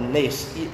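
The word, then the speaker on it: Nes (נס): The first one is it's spelled Nes, N-E-S, which is a military banner.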